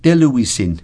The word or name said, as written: teluisin